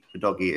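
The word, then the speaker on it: Udogie: Udogi is how you pronounce his name.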